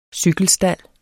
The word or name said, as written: cykelstald